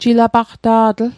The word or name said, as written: jilapaqta'tl